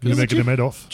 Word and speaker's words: Nurmagomedov: Nurmagomedov.